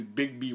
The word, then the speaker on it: Bigby: His name in there is Big B Wolf. It's spelled B-I-G-B-Y.